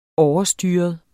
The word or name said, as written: OVERSTYREDE